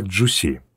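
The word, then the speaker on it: Джуси (主席): По-китайски эта пара иероглифов читается как «Джуси».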